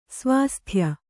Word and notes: ಸ್ವಾಸ್ಥ್ಯ: svāsthya

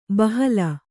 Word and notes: ಬಹಲ: Kannada

